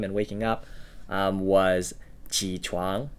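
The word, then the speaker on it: chuang (床): And then the second syllable, chuang, right, chuang, that's using the CH sound, which is kind of less of a clean sound and more air escaping your mouth and the rounded lips.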